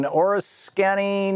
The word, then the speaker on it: Oriskany: Okay, Bill, in Oriskany, New York.